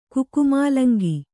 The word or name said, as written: ಕುಕುಮಾಲಂಗಿ